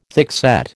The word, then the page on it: thickset: American English